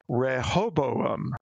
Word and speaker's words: Rehoboam: Okay, so here's the trick to pronouncing the word correctly. And the trick is to say hobo and attach two vowels and a consonant on either end. – Okay. – so it's Rehoboam.